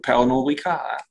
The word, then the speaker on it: Pernod Ricard: Und da gab es erst einmal sehr, sehr gute Zahlen von Pernod Ricard. Habe ich es richtig ausgesprochen? – Ich glaube schon, ja. Das wird Französisch, ein Franzose wird wahrscheinlich Französisch aussprechen. Pernod Ricard.